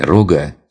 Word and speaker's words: рога: Какой-нибудь начинающий разбойник, или как его чаще называют в игре рога, с ударением на первый слог.